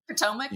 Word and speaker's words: Potomac: How do you pronounce Potomac in Washington D.C.? You can Potomac.